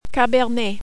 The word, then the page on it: Cabernet: French